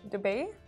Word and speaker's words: Dubai: and it's like when people don't even know how to say Dubai, and they go like Dubai. It's like oh my god. – That is how you say it. Dubai.